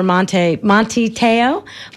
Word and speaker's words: Manti Teo: For me, I was just heartbroken for poor Manti Teo, that's how you say it.